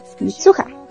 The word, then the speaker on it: Mitsuha: Esta película trata de, bueno, tenemos dos personajes principales que son Taki y Mitsuya, Mitsuha, Mitsuha.